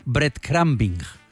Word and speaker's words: breadcrumbing: Que es el, no sé si lo voy a pronunciar bien, es un término inglés. Breadcrumbing. Breadcrumbing. – Perfecto.